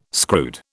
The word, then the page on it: screwed: British English